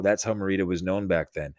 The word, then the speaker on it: Morita: And they're like, "Yeah, that's Pat Morita, or however you pronounce it. Pat Morita, yeah.